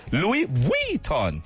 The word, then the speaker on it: Louis Vuitton: Louis Vuitton. Ah, good luck. Good luck telling Bay, please buy me a Louis Vuitton bag. Eh? Ah. – Xiaomi. – Hmm? – Xiaomi. – Spelt X-I-A-O-M-I.